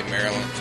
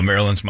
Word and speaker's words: Maryland: It is said as Maryland. Maryland. Stress on the first syllable.